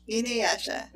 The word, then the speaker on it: Inuyasha: You just helped me with my pronunciation. Inuyasha. – Inuyasha. – That's correct? – Correct. – Okay.